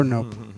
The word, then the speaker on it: Knopp: Nope. Nope. – Nope. Nope. – Nope. – Nope. – K-N-O-P-P. – Oh. – Nope. – Not as much fun. – Is it Nope or Nope? – It's Nope.